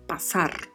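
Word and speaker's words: pasar: Não levemos para o espanhol o raciocínio do português de que S entre duas vogais tem som de Z. O som permanece com som de S. E o R tem sempre o som vibrato. Então pronunciamos passar. Passar.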